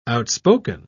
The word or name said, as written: outspoken